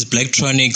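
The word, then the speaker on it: Blaqtronic: My name is Blacktronic, so it's pronounced BLAQ. TRONIC. And on Instagram, it's at Blacktronic.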